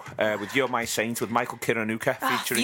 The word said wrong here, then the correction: Kiwanuka: You said it wrong. – Did I? – You said Kiwanuka. – Come on, correct There's me. There's no R in there. It's Kiwanuka.